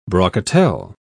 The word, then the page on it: brocatelle: British English